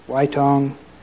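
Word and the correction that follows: Ytong: Whitong is pronounced yitong.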